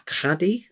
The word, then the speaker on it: qadi: So he's a judge, uh, or what's known as a, a caddy, right. Q A D I, uh, caddy, I think it's pronounced.